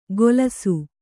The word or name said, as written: ಗೊಲಸು